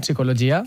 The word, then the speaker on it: psicologia: Per exemple, si anem a PAMS, en la primera, en psicologia, uh-huh. Sense pronunciar la P, sí. – Ahí no hi ha dubte.